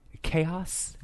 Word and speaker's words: K-OS: My next one, this is a guy named Chaos. It's spelled K-O-S.